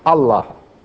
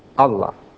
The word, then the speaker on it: Allah: a compound word, pronounced Allah, Allah, meaning the God, the God.